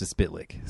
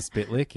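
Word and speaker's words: Mxyzptlk: And it actually said, like, when he pronounced his name, it was like, Mr. And then it had, like, him spitting and then licking. So, Mr. Spitlick, yeah.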